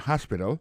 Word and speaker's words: hospital: L'hôpital, c'est comme ça, on dit ça en anglais, hospital. Alors, accent tombe sur le premier syllabe, il y a trois syllabes. She likes to visit hospital. Ensuite, elle a écrit H-O-S-P-I-T-A-L.